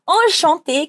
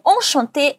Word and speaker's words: enchanté: Como Marie é uma mulher, o enchanté ganha um ezinho a mais. Se fosse homem, a gente pararia no E acento agudo. Mas repetindo, a pronúncia é a mesma. Feminino, enchanté. Masculino, enchanté.